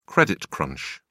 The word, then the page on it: credit crunch: British English